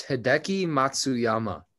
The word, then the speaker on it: Hideki Matsuyama: I believe it's Hideki Matsuyama.